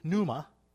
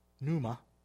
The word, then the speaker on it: pneuma: The Spirit here, when you're looking at that, the meaning of it and the way it would be pronounced, it comes out of the Greek, would be pneuma. Pneuma.